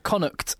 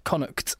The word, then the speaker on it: Connacht: No, the pronunciation of Connacht, because I think it's the you have the k before the t. Connacht. – Connacht. – I think it's Connacht.